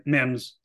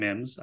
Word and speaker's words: Mims: Marvin Mims, excuse me, Marvin Mims. I said it properly the first time.